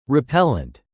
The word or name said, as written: repellent